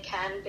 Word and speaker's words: Cannes: So she had to make her way down to Cannes, or Cannes. I say Cannes because that's how you say it in French.